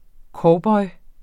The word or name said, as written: COWBOYEN